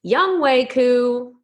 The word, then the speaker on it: Younghoe Koo: It is correctly pronounced Younghoe Koo.